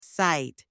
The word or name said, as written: sight